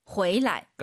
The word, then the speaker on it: 回来: 호이라는 글자 뒤에 라이나 취가 오면은요, 방향보호로서 경성입니다. 호이취 해도 알아듣지만, 호이취, 돌아오다, 호이라이 해도 되지만, 回来. – 가 정확한 발음이에요.